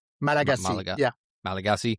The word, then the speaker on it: Malagasy: And that's the Malagasy people. Is that how it's said? – Malagasy.